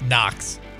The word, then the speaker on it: Knox: K, but the K is silent. – Oh. What's it sound like it starts with? – Knox.